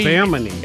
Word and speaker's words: Bimini: And by the way, here's how you say it. That was not far off. To me, Bimini. – If I was grading if I was grading his test, he gets correct answer.